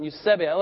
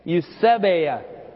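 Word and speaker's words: eusebeia: Sorry, the emphasis on Eusebia. Let's all say that. Eusebia.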